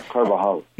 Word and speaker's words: Carvajal: And I loved reading about the Dominican friar Gaspar de Carvajal. Is that how you pronounce it? – Carvajal. – Yes.